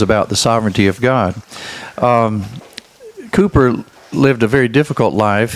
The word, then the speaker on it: Cowper: I used to pronounce it Cowper, but it's really... – Yeah, I got to talk loud. – Okay, it's pronounced Cooper if you're from England, and I didn't know that for many years.